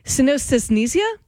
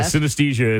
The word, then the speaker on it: synesthesia: Says, look up, I'm not going to pronounce this correctly, synesthesia? – Oh, synesthesia.